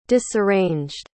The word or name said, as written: disarranged